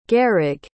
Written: gehrig

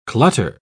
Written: clutter

